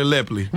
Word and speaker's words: Lepley: Miracle Watts and Tyler, isn't it Lepley? I thought it was Tyler Lepley. – Everybody I've ever heard pronounces Tyler Lepley.